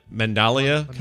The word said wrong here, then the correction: mandala: coloring? – Mandala.